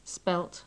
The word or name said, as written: spelt